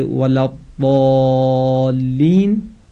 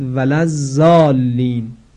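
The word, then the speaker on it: ولا الضالين: اگر کسی ضاد رو در ضالین به شکل زا تلفظ بکنه، مثلا بگوید ولز زالین، زالین با زا به معنای ریخته شدگان است، در حالی که کلمه ضالین به معنای گمراهان هست.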